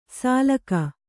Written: ಸಾಲಕ

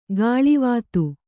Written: ಕಳಾವತಿ

